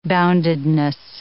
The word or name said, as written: boundedness